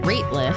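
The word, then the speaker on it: Rateliff: Nathaniel, I think his last name is pronounced Ratliff, and the Night Sweats is the name of the band.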